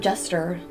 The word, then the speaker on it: jester: I know what a gesture, like a court gesture is, but like. – A gesture? – Am I not saying it right? – Jester. – Jester? Yeah. Okay.